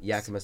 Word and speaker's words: Yakima: And the first home game will be the next weekend on March 13th against the, is it Yakima? Is that how you pronounce it? Yakima Sun Kings? – Yakima Sun Kings.